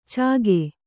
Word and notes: ಚಾಗೆ: Kannada

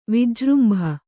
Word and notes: ವಿಜೃಂಭ: vijřmbha

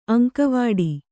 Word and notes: ಅಂಗವಡಿ: aŋgavaḍi

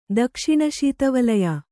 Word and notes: ದಕ್ಷಿಣಶೀತವಲಯ: dakṣiṇa śita valaya

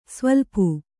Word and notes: ಸ್ವಲ್ಪು: svaḷpu

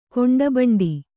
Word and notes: ಖಂಡಭಂಡ: khaṇḍa bhaṇḍa

